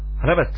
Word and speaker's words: Rabat: Rabta. – Rabat. How do you spell it? – R-A-B-T-A.